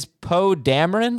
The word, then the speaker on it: Poe Dameron: Jamey is Poe Dameron. Did I say that right? Yeah.